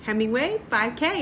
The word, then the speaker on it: Hemingway 5K: You have to say it right. Hemingway 5K. It's a rhyme.